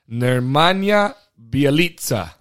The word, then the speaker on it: Nemanja Bjelica: Nemanja Bjelica.